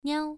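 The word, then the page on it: niao: tone 1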